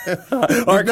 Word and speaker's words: Arkhan: Arkhan. No, you were going there, and then you doubted yourself. – I did. I had a little moment of doubt. – Yeah. – Arkhan, yeah.